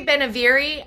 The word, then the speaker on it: Benevere: Did I say that right? Sorry, I always feel like I mess it up. – I say Benevere. I think Benevere works too.